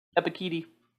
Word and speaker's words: Ebiketie: Give me Ebikidi here. – Ebikidi? – That's how they say it on, that's how Bengal pronounces it. – Unreal. – Ebikidi.